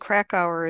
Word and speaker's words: Krakauer: This is nonfiction, and it's called Classic Crack Hour, and Crack Hour is spelled K-R-A-K-A-U-E-R. It's by John Krakauer, who is the man who wrote Into Thin Air.